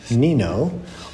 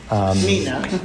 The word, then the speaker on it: Nino: Before we get started, I'm thankful Shemesi Aina reminded me that today is the feast of Saint Nino, or Nina. – Nina in English is often how that's pronounced,